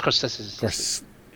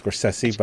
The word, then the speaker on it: Scorsese: It's pronounced Scorsese. – It is pronounced Scorsese, but I didn't want to get into that with you.